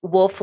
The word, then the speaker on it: Woolf: My first name is spelled K-E-L-I-E. My last name is Wolf, like the animal, only with two O's. So W-O-O-L-F.